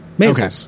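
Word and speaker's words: Maysles: Maisel's. Okay. It's like measles, but with an A.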